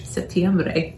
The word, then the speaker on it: Septiembre: Septiembre.